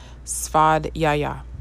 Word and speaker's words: svadhyaya: Today is niyama number 4, svadhyaya. Svadhyaya, which is a little hard to get around the pronunciation. It's actually spelled S-V-A-D-H-Y-A-Y-A.